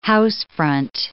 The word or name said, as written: housefront